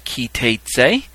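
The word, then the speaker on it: Ki Teitzei: This is Parashat Ki Teitze. It's pronounced Teitze. Say ki, like a key that you put in a lock. And then Teitz, T-A-T-E-S. And then the word say, Ki Teitze.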